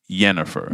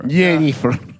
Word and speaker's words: Yennefer: So instead of Jennifer, it would be Yennefer. – Yennefer.